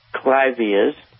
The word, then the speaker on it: clivia: Hey, Paul, I have three clivias. I think that's how you pronounce it. – Yep.